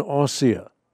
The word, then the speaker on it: Asiya: You can pronounce her name in a westernized sense as Asiya Bibi, but let me use the Arabic pronunciation, Asiya.